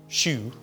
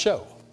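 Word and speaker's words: shew: Well, I never paid attention to that because in the first place, in the King James, it's S-H-E-W, shoe, but it's pronounced show.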